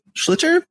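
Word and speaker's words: sliotar: the schlitter, am I pronouncing that right? – Perfect.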